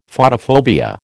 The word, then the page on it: Photophobia: American English